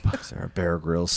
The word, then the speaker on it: Bear Grylls: Netflix's next interactive series puts you in charge of Bear Grylls. Is that how you say his name? – Yes.